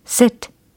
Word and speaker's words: sit: sit, sit, sit. 일단 이 발음에도 좀 집중을 하실 필요가 있어요. sit 아니고 sit. 네, 뭔가 짧아요. 그쵸? 그러면서 약간의 다크한 발음입니다.